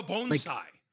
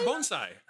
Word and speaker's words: bonsai: They have a bonsai. like a bonsai, but it's a mushroom. You know, like bonsai trees? – Yes. – No. Oh, bonsai? – Yeah, bonsai.